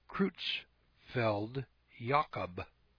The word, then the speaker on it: Creutzfeldt-Jakob: This is the name in people for mad cow disease, and it's pronounced Creutzfeldt-Jakob disease,